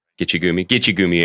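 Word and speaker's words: Gitche Gumee: And, you know, knowing that now hearing you say that Nick suggested one small thing that landed this Gimme Gucci Air, did I say it right? Gitche Gumi airplane Gitche Gumi, of a series.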